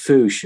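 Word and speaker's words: fossa: And then the only carnivore is something called the fouche, which is spelt as if it's fusa, but it's pronounced fouche.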